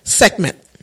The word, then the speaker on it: Sekhmet: I'd say Sekhmet. – Okay. Sekhmet. And Sekhmet birthdays are July 29th to August 11th, October 30th to November 7th. Moi. Okay, and Cruz. Okay. Sekhmet, your power is high.